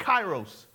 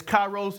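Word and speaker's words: kairos (καιρός): This is translated from a Greek word called kairos. We've heard this, or maybe you've not heard this. The transliteration is K-A-I-R-O-S, but I can't spell it in Greek. I barely can spell in English, but the transliteration is kairos. Everybody say kairos.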